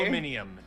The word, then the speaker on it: aluminium: It's like aluminium. There's just an added what? – An added syllable. – That's aluminium. How they say aluminum.